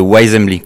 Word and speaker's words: Wisembly: On va parler avec vous euh, de Wise c'est ça Ah, je l'ai mal dit. – On va parler de Wise Assembly, comme une Wise Assembly. Wise Assembly. – Wise là je le dis bien.